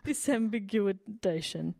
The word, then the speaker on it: disambiguation: Sambiguification? I've never said that word out loud before. – Sambiguation. – This is what I added a syllable. – Disambiguation.